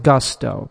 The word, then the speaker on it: Gusto: I pronounce it Gusto because it's the English word. Gusto is an English word.